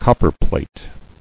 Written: Copperplate

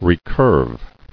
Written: Recurvation

